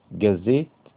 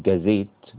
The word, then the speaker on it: gazette: gazette اللي هي صحيفة، أو gazette بالتخفيف في الزاي، يبقى برضو صحيفة الاثنين واحد، بس تنطق gazette أو gazette، خلاص كده.